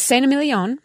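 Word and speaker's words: Saint-Émilion: It's Saint-Emilion.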